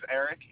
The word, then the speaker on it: Eric: My name is Eric, E-R-I-C. – And the last name? – Gautler is spelled G-O-U-G-H-L-E-R. – G-O-U-G-H-L-E-R? – Yes.